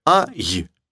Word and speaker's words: I: Смотрите. Они говорят «а-й», а мы говорим «и-а». Просто звуки переставьте, и у вас то же самое получится.